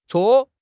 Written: 搓

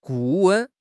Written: 顾问

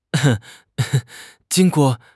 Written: jing1 guo4

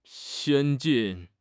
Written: 先进

